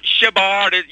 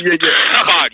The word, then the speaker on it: Chabad: You have to pronounce it right. It's not Shabad. You know, that sounds innocuous. It's Chabad. You almost have to spit on someone to pronounce the name.